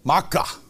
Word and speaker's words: Maacah: How do you pronounce it? Where are you? – Maacah. – Yeah. Well, I'm close. I just didn't put the emphasis in there.